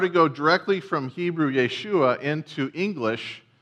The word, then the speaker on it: Yeshua: But Jesus' name in Hebrew is Yeshua.